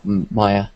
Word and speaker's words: Maya: It's on a mass multiplayer online RPG, but it's full-time, and you need ZBrush and Maya experience. Maya. Sorry. I pronounce things stupidly.